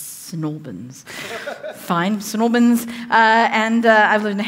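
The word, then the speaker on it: St Albans: Snorbins, I got to say it like that.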